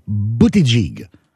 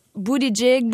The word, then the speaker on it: Buttigieg: La bonne façon, c'est Buttigieg. – Oui, ben Buttigieg.